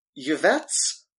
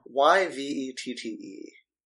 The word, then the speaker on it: Yvette: Coming at number four, it's Yvette's Process. How do you pronounce that? Y V E T T E. – Yvette. – Yvette. Okay.